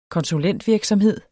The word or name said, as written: konsulentvirksomhed